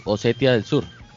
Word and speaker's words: Osetia del Sur: Abjasia y Osetia del Sur lo, pronunciaste perfecto.